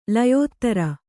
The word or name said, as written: ಲಯೋತ್ತರ